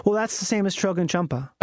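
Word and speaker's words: Chögyam Trungpa: Do you know about this monk named Trogen Chump? I always say his name wrong. He's a Buddhist monk. – Yeah. – And his name is Trogen Champa.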